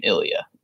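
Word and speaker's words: Ilia: I pronounce her name Ilya. – I do too, actually.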